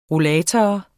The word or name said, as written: rollator